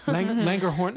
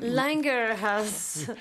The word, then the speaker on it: Langerhans: Langerhorn? – Langerhans.